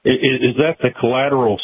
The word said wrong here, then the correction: colloidal: silver you're referring to? Or it's called colloidal. – Colloidal. I can't hardly pronounce it.